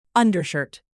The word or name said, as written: undershirt